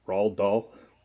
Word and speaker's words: Roald Dahl: All right, so number 8, I went with a classic based off the Roald Dahl. How the hell do you say his name? – No, you were right.